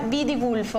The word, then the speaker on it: Vidigulfo: Un'intenzione di preghiera da Nina da Vidigulfo, ho detto bene? – Sì, sì.